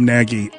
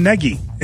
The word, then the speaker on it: Nagy: Everyone that talks about him calls him Nagy. And then I saw Patrick Finley tweet out that he asked him, what is the pronunciation of your name, and he told him Neggy, N-E-G-G-Y.